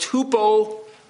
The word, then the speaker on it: hupomone: And so the original Greek word is pronounced hupomone.